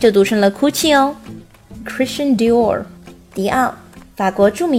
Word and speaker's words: Christian Dior: 它不读 Dior，不是迪奥，正确的读音是 Christian Dior。 Christian Dior，注意我们在说到 Dior 的时候，卷舌音要发到位哦。另外，第一个单词 Christian，Christian 后面是 shin 而不是 tin。